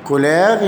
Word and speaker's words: couleurs: كولير يعني لون، أضيف لها إس لا تنطق، يعني كولير يعني ألوان، لي كولير يعني الألوان.